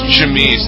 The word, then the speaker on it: chemise: How do you say this word? Is chemise? Is that what it is? Or chemise? Or what is chemise? – Chemise. There you go.